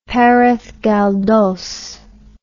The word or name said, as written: Perez Galdos